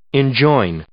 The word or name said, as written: enjoin